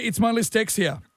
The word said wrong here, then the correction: dyslexia: Oh, dyslexia.